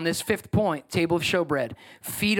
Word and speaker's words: showbread: It looks like shoe bread. It's showbread is how it's pronounced.